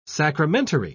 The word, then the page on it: sacramentary: British English